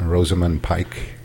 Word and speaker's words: Rosamund Pike: Rosamund Pike. Is that how you pronounce her name? – Yes.